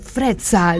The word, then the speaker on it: Frezza: Y del otro lado del vidrio tengo a nuestra operadora Flor Frezza. ¿Lo dije bien, Flor? Me encanta pronunciar la doble Z del italiano, frezza, como pizza, como puzza.